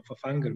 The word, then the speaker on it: Fafangel: Ne, kot je gospod Fafangel, v, eh, oziroma Fafangel, mislim, da se pravilno izgovori,